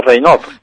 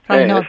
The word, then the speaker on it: Raynaud: Bueno, yo no sé francés, pero se pronuncia Raynaud. – Raynaud, pues venga.